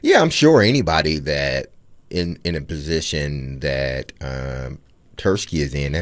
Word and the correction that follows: Tulsky: Eric Tulsky.